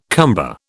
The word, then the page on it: cumber: British English